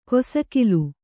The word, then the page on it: ಕೊಸಕಿಲು: kosakilu